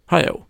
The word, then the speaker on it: Hayao: Eh, gjord av Hayo, Haya, Hayao, uttalas det så? Visst måste det uttalas så? – Hayao?